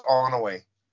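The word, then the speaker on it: Onoway: Onoe? Is that how you say it? Onoe? – It's just Onoe.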